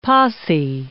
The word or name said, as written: posse